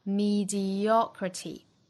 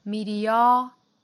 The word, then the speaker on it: mediocrity: 那我们经常讲到单词如果是以字母 y 结尾的一个多音节单词的话，重音呢一般来讲是会在倒数第三个音节的 mediocrity，哒哒哒哒哒 mediocrity。美音呢要注意就是 o 这个音呢、读成 o，开口度更大更松弛 mediocrity mediocrity，最后的一个 t 的音呢美音更多会弹出来，因为有元音嘛，所以美音会发成类似于 d 的发音 mediocrity mediocrity。